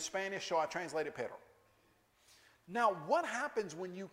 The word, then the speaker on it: perro: Perro. You're supposed to be able to roll your R's. I can't do it real well. P-E-R-R-O.